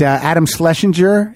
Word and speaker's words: Adam Schlesinger: We got Adam Schlesinger.